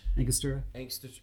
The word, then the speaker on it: Angostura: Three dashes of the, I'm going to mispronounce, what is it? It's Angostura? – Angostura.